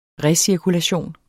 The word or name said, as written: recirkulation